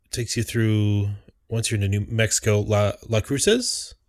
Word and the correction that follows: Las Cruces: Las Cruces. I probably said that wrong. Las Cruces, bro. – Yeah, Las Cruces.